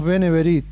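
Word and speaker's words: B'nai B'rith: Acá le decimos Beneverit, sí.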